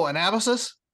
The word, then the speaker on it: Anabasis: He says, oh, Anabasis? And I'm like, oh, well, okay. If that's how you want me to say it, I'll say it that way.